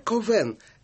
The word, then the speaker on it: Ковен: Отец Жерар Ковен – это первоначальное произношение имени Кальвин. Затем оно как-то... – Нет, это французское произношение. – Французская вариация. – А Кальвин, да, это более латинизированное.